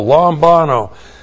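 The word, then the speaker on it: lambano (λαμβάνω): and then the verb lambano. Looks like that in the Greek, L-A-M-B-A-N, long O, lambano.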